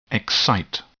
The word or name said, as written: excite